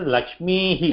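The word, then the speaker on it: लक्ष्मीः: Here, see Lakshmihi. You have to pronounce it as Lakshmihi.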